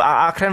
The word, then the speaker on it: Akren: Ah, wird schon mal anders. – Das wollte ich sagen. Wird anders ausgesprochen. Akren wird es natürlich ausgesprochen.